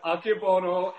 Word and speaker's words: Akebono: Aki Bono and Masu Naru. – Musashimaru. – yeah. That's great Japanese. Aki Bo- yeah, Akebono and Musashimaru.